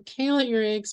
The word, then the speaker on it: Ihrig: I'm Kayla Ihrig, spelled with an I.